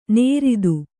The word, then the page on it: ನೇರಿತು: nēritu